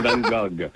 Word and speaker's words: Van Gogh: Bon, dans la langue néerlandaise, dans sa langue natale, c'est Van Gogh. Oui, et en France, c'est Van Gogh.